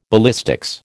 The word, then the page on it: ballistics: American English